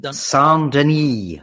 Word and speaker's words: Saint-Denis: Yeah, and we've got to talk about, and again, I always like to butcher these, Benoit Saint-Denis. – Saint-Denis, I think is how you say it, if you're going for the French pronunciation.